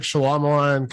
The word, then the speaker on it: Shyamalan: It's kind of like Shyamalan coming back. And I'm saying his name wrong. – I haven't seen that one. No, you're saying it correct.